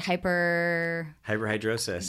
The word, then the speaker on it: hyperhidrosis: One of them, am I going to say it right? Hyperhidrosis. There we go.